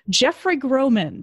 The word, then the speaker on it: Jeffrey Groman: We have Jeffrey Groman.